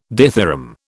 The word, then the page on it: dithyramb: British English